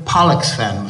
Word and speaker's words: Pollexfen: Elizabeth Pollexfen was her name. We've got to pronounce that word very carefully. Pollux, P-O-L-L, in case you think it's something else.